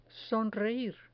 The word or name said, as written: sonreir